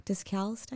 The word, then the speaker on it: Discalced: Because there were the ordinary Carmelites and then the discalced. Did I say it right? – Discalced, I think.